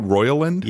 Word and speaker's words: Roiland: So on January 12th, NBC News revealed that Roiland, am I saying that right? Roiland? – Yep.